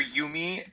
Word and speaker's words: Jumi: Okay, so I'm currently working for Yumi Cheese, which, yeah, as Diane, as you said, it looks like Jumi because it is spelled with a J, but it's Swiss-German.